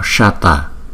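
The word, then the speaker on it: Śata: Chẳng hạn như là chữ S này chúng ta đọc Shata, còn ở dưới đây đọc là Swaha.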